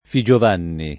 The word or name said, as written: Figiovanni